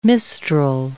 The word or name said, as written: mistral